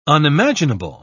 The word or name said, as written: unimaginable